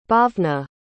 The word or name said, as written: bhavna